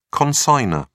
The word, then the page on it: consignor: British English